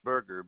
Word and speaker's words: Berger: 85711, Leonard Berger. – Can you spell that last name for me? – Berger, B-E-R-G-E-R.